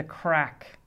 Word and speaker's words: craic: And there's a thing in Ireland, which Americans probably haven't heard of, but there's a thing in Ireland called the crack. And it's spelled C-R-A-I-C. It's an Irish word and means fun.